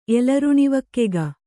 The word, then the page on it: ಎಲರುಣಿವಕ್ಕೆಗ: Kannada